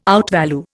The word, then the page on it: outvalue: Indian